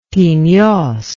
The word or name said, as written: Peneios